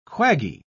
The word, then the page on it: quaggy: British English